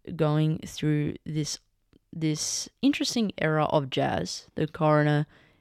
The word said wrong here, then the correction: Crooner: Oh, I believe I pronounced that wrong, but this Crooner, sorry.